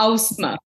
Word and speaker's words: Ausma: Ah, yes, very close. Well done. Yeah. It's Ausma.